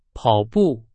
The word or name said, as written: 跑步